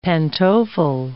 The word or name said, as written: pantofle